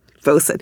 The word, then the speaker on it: boatswain: But also just within English, I found out in this reading that I've been pronouncing boatswain wrong for, I don't know, 20 years. How do you say it? Bosun, which doesn't make any sense to me because boatswain is so much more elegant.